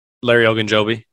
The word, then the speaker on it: Ogunjobi: Larry Ogunjobi.